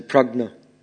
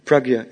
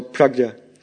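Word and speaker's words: prajna: Another point which very much struck me in the Prabhupada purport was the term Pragna. How do you pronounce it correctly? Can anyone give me some? Pragya. Is it Pragya? Okay. So, Pragya.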